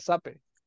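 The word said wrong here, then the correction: Zappe: A, Zape, no sé, ¿cómo se pronuncia? Eh, es Zapi.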